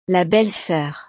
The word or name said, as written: la belle soeur